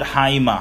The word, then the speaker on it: haima: Alright, as we close, the word blood, like I said, is the word haima. Haima is spelled H-A-I-M-A 99 times.